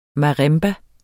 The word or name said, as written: marimba